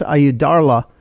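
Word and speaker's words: Ayyadurai: I think his last name is pronounced Ayudarla.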